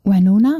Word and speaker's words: Winona: Winona, Winona, ja.